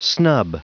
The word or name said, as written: snub